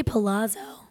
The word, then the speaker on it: Palazzo: Is it Palazzo? Is that how you say it? Or Palazzo? – I say Palazzo.